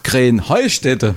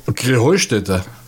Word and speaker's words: Krähenheustätte: Ja, ihr saget immer Krenheinstetten. Und dabei heißt es doch Krähenheustätte, oder wie? – Krähenheustätte. – Ja, genau, und das die Betonung auf dem Heu.